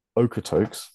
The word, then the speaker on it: Okotoks: Okotoks, yeah, I believe that is more correct, which is just south of Calgary in Canada.